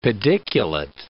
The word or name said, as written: pediculate